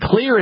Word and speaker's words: Xlear: Clear Rescue Nasal Spray. And Clear is spelled X-L-E-A-R. The X stands for xylitol,